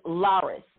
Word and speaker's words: Lyris: My name is Lyris, and it's spelled L-Y-R-I-S, and people cannot say that name.